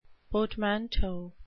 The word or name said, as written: portmanteau